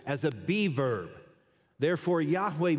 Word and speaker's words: Yahweh: It was YHWH, and that's pronounced Yahweh. In the biblical Hebrew, there are no vowels, so there are no A-O-I-O and U's, right?